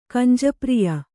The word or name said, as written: ಕಂಜಪ್ರಿಯ